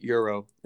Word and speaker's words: gyro: A gyro, for those who don't know Greek food, but gyro is how it's pronounced.